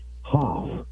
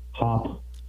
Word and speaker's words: Hopf: So I was hoping to get a call from Colonial Downs' new OTB director, Frank H-O-P-F, Hoff. I guess that's how you pronounce the new director of OTBs at Colonial Downs. Hoff.